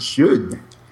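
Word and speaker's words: Sjón: The unpronounceable Icelander, Sean. – Yes. – Or something along those lines. – Shun. – Shun, yes. It's a shun.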